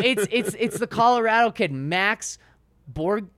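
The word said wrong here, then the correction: Borghi: Oh yeah, Max Borgie. – Borgie, yes. I just didn't know how to say his last name.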